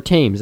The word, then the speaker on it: Thames: More pieces of the original London Bridge, 2,000 years old, and bearing Roman inscriptions, have been found on the banks of the Thames River, or Thames, as it's Thames. – Pronounced. Thames.